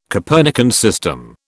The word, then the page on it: Copernican system: British English